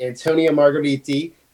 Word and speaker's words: Antonio Margheriti: And that is the 1980 Antonio Margheriti. I said that right, hopefully. – That's perfect, yeah.